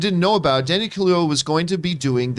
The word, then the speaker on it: Kaluuya: Kaluuya. – Kaluuya, thank you.